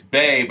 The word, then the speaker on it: Boeh: And then, but my last name, it's Bay, but it's spelled B-O-E-H. – Okay. – It's German. So the O is silent or whatever.